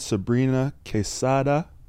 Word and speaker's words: Sabrina Quesada: Today, as I mentioned, I'm joined by the wonderful and talented Sabrina Quesada.